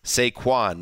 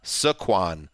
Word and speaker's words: Saquon: It's Saquon, not Saquon.